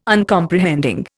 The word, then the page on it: Uncomprehending: Indian